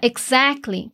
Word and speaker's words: exactly: And that's exactly. Essa palavra exactly, que é exatamente, tem uma pronúncia bem diferente. Vamos dividir ela. Eggs. Ac. Glee. Exactly. Continuando.